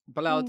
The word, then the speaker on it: Bilal: It's pronounced Bilal.